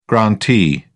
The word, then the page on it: grantee: British English